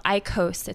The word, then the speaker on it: IQOS: It's all about reduced-risk smoking and, quote, smoke-free products, which are, on the Philip Morris side, a product called IQOS. It's I-Q-O-S. And that is launching... – See, that's how you pronounce it.